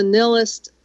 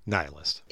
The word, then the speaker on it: nihilist: Lestrade is a nihilist. Am I saying that right? – No, not even close. – I knew I wasn't. Nihilist? – Nihilist.